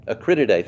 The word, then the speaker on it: Acrididae: Wikipedia says that locusts are a certain species of short-horned grasshoppers in the family Acrididae. Did I say that right? Acrididae. Thank you very much.